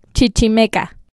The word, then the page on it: Chichimeca: Spanish